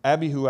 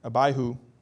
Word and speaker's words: Abihu: It says, now Nadab and Abihu, I think that's the way you pronounce it, Abihu,